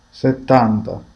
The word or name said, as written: 70